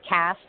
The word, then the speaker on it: Kass: Okay, so my full name is Cassandra Rose for social media purposes, and my book, I did use my nickname, which is Cass, and I am spelt with a K, so K-A-S-S, Rose, like the flower.